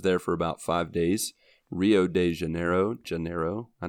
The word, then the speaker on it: Rio de Janeiro: In Portuguese, you say it Rio de Janeiro, which is quite different than what we say uh, because their R's at the beginning of the bit. words are pronounced like an H.